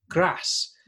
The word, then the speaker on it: Grasse: And the race actually passed through Grasse, uh, yes.